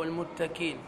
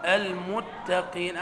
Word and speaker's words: المتقين: قاف ما تعلاش درجة زايدة عن درجة متقين أو المتكين. لا هذا ولا ذا. المتقين. هذه الدرس الثاني، اليوم الثاني في المعهد درست هذه القاعدة. ثاني يوم في المعهد سورة البقرة هدى للمتقين. المتقين. ويخطئ البعض إذا نطقها كافا والمتكين ورايح راسه يقول لك زي الشيخ مصطفى.